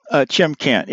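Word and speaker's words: Shymkent: Chimkent, yeah, excuse me. That's S-H-Y-M-K-E-N-T.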